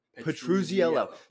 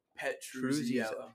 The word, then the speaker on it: Petruzziello: Petruzziello.